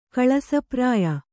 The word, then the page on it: ಕಳಸಪ್ರಾಯ: Kannada